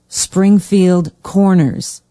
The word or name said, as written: springfield corners